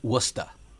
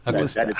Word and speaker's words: Worcester: So first of all, it's not Worcester. It's Worcester. – Worcester. – That's true. That's right? – Correct. – Worcester.